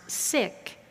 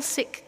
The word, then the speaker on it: Sikh: That's spelled S-I-K-H, and it's properly pronounced Sikh. I used to think it was pronounced Sikh, but the community is called the Sikh community, and the religion is called Sikhism.